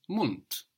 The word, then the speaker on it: Mund: But the D being on the end of the syllable is being pronounced hard. Mund, mouth.